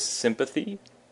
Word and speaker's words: sympathy: So the next word is sympathy. Sympathy, with the emphasis on the sim, sympathy.